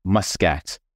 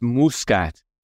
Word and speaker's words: Muscat: And I have to say, I have to get used to being the North American pronunciation of my surname, Muscat. Because here in Malta, it's Muscat.